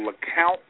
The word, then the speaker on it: Lecompte: majority black town called LeCount, Louisiana. It's called, it's spelled L-E-C-O-M-P-T-E, Indian word.